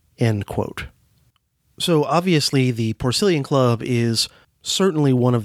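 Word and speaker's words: Porcellian: Now, if you look at the name of the club, Porcillian, while it's spelled the same, the root pork, P-O-R-C, in the Porcillian Club, which is pronounced with a soft S in that case, is a very different word root from the P-O-R-C, pronounced with a hard C, in, say, Porkfest, which I attended back in the middle of the summer.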